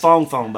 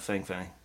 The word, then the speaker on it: Fang Fang: It's Fong Fong, by the way, not Fang Fang. It's spelt like Fang Fang, but it's Fong Fong.